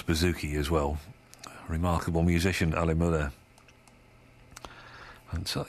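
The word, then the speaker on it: Ale Möller: And his name is not spelt the way it sounds. Ali is spelt A-L-E, as in beer, and Möller is M-O umlaut, right? So the two dots over the O, M-O-L-L-E-R, Ali Möller.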